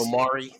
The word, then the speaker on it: Omari: Dave Bautista, Ella Parnell, Anna de Reguera, Omar Hardwick, Theo Rossi, Matthias. – It's Omari, thank you. – It's Omari Hardwick. – Oh, sorry. Omari. I said his name wrong.